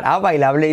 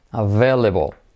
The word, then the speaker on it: available: Escrito a bailable. Oh my God, a bailable y se pronuncia available.